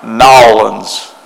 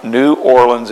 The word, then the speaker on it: New Orleans: We did have an opportunity we flew into New Orleans, and let me say it right, it's Nolens. It's Nolens. It's not New Orleans, it's Nolens.